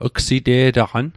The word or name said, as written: gsite'taqan